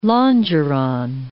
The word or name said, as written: longeron